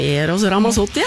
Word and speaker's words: Eros Ramazzotti: Ja vel, Eros Ramazzotti. – Ja, nettopp, det var sånn man sa det. Du har øvd det. – Nei, alle vet vel hvem Eros Ramazzotti er.